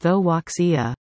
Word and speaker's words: Toaxia: Currently free of COVID and with a series of bright, sparkling reviews behind the family-friendly tale, Toaxia, pronounced Thoaxia, is on a planned tour in Western Canada with a stop at Arden Theatre on Sunday, October 30.